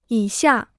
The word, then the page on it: 以下: yǐ xià